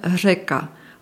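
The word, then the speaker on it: Řeka: Der Buchstabe R mit Häkchen ist dafür einmalig und für die deutschsprachige Zunge schwierig. Ausgesprochen wird es als R, zum Beispiel Reka, auf Deutsch Fluss, oder Tri, drei auf Deutsch.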